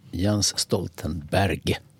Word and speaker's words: Jens Stoltenberg: Jens Stoltenberg, så uttalas han på norska.